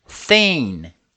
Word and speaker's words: thin: Thin. O magro, em inglês, ele começa com aquele TH, então a pronúncia é thin.